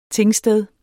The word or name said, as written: tingsted